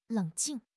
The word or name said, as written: leng3 jing4